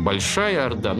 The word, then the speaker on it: Большая Орда: Ну, обычно у нас это пишется в литературе как Большая Орда, но, скорее всего, здесь имелся в виду смысл, что она главная. То есть, говорят современным языком, ударение надо ставить на первое слово «большая орда». Главная из орд.